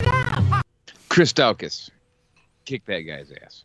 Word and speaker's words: Daukaus: Daukaus, if you'd like to pronounce it that way, which we do.